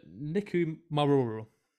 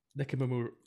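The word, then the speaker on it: Nikumaroro: Now, the theory is that the plane landed in what is called, now, excuse my pronunciation, but Nikumaruru, right? Which is basically, I think that's a very good pronunciation, that is. – That was excellent. – Nikumaruru.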